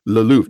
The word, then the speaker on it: le Louvre: No, no, le Louvre. – Yeah. – I really don't care that I'm not pronouncing like a little tiny bit of the R right there. I just want to know where the art museum is. And he would not let it go until I pronounced it correctly.